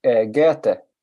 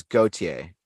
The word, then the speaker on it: Goethe: You wouldn't know this, it's hard because it's German, but I every time you say his name I jolt. I don't know which one you mean, but then I remember you because you're the pronunciation, but it's Goethe.